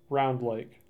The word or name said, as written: Round Lake